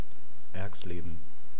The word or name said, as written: Erxleben